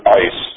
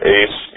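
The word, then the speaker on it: ice (εἰς): Into is the Greek preposition ice. Some pronounce it ace, you'll hear that, which shows identification.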